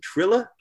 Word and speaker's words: Triller: And then we're going to go over to, I guess we'll call it boxing YouTube, over with Triller. Is that the right pronunciation? Triller? – Triller. – Triller. Like Triller. Okay. – Like thriller without the H.